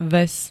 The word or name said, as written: ves